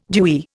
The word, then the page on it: dewy: Indian